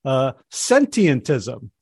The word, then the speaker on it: sentientism: So we're going to talk about a new word for me, sentientism. First of all, did I say it right? – Yeah, that's a great start. Your pronunciation was perfect. It has a few too many syllables, but you got it.